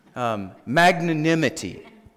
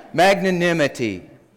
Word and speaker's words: magnanimity: Magnanimity. Say that with me. Magnanimity. Is that right? Did I say that right? Yeah. Magnanimity. That has to do with magnifying something other than ourself.